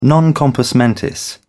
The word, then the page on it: non compos mentis: British English